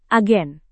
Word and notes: agen: indonesian

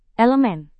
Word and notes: elemen: indonesian

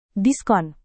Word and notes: diskon: indonesian

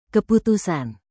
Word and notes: keputusan: indonesian

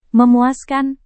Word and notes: memuaskan: Indonesian

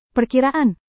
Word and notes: perkiraan: Indonesian

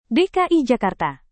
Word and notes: dki jakarta: Indonesian

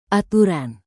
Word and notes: aturan: indonesian

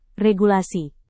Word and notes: regulasi: Indonesian

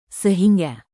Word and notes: sehingga: indonesian

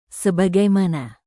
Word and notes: sebagaimana: indonesian

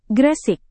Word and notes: gresik: Indonesian

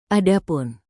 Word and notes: adapun: indonesian

